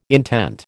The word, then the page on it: intent: American English